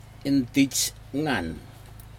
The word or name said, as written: intigag